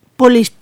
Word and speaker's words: Polis: Bueno, voy a empezar a leer Popolis. No sé si ahora lo he dicho bien. Y bueno, Polis, y yo con Popolis, qué manía.